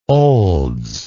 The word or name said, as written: olds